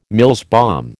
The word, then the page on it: Mills bomb: American English